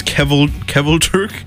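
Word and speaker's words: Kvelertak: This is Kevelturk. kind of like Norwegian. – I believe they're, like, they're Swedish. – Swedish? You're right. No. I don't know. Foreign. And Kevelturk, so it's...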